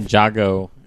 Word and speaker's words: Ninjago: Seems like a pretty slow week outside of Ninjago. Is how they pronounce it.